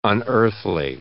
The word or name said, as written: unearthly